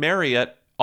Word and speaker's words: Marriott: Turns out we've been pronouncing Marriott all wrong. It rhymes with chariot.